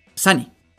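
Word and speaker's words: Sanni: Entonces, que es su novia Sony. Entonces, Sani, perdón, lo he dicho mal todo ese tiempo, qué menso yo.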